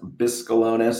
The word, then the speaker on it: Bescalonis: Last name pronounced Bescalonis, so you were close. – Yeah, Bescalonis, is that Greek? – 99% of the time, people guess it's Greek. Couple Bescalonis with Nick.